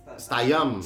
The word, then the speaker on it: Statham: Oh, Just, stagnan tuh artis Jason Stagnan. – Stayam. Statham. Bacanya Stayam.